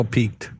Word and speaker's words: pique: You're a little peaked. – That's P-E-A-K. – Okay. – Or your interest is peaked. – Peaked is how you pronounce that. – P-I-Q-U-E.